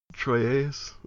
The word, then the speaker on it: Troyes: Bishop of Bishop Ansiges of Troy. Troyes. I really ought to be able to pronounce French better. Troyes. Troyes. Let's go with that.